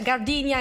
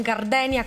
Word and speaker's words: Gardenia: Meno male che ci sei tu a ricordarmi che si pronuncia Gardinia e non Gardenia come avevo letto io dal nome, il titolo di questa canzone, Gardinia di Iggy Pop.